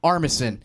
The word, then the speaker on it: Armisen: I always pronounce Armistead. – It. Armistead. Yeah, I always, I don't know. – I used to watch SNL when he was on. It's Fred Armisen.